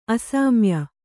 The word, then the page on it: ಅಸೌಮ್ಯ: asaumya